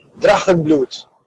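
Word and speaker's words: Drachenblut: Probably Nazi is not a good word because it is a German word, but there is no K in Drakenblut. It's German. So, since this is one of the languages I speak, it's pronounced Drakenblut. And it's the same as in Holapido. So, Drakenblut. So, just think Dra, Holapido, but without the Holapido. Blut, Drakenblut.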